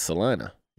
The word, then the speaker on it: Salina: Well, in some places it might be Salina, but not in Kansas. It's Salina.